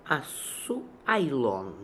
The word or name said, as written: Asooailon